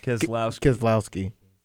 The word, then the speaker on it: Keselowski: Keselowski.